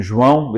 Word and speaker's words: João: My name is João Paulo, but I introduce myself always as JP, because one day this British person was trying to pronounce my name, João, which is quite nasal.